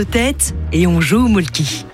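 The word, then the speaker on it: Mölkky: Inventé en Finlande en 1996, ce jeu de quilles s'écrit M-O-T-R-E-M-A-L-K-K-Y. – Ça s'écrit Molki, mais ça se prononce Molke. – Le Molke. – Plutôt un E qu'un E, mais pas un U. Donc ça fait Molke.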